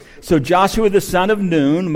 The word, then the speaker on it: Nun: the son of Nun, Moses' assistant, saying, now Joshua, the son of Nun, and literally in the Hebrew, that's pronounced noon,